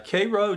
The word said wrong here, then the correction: Caro: Robert Caro.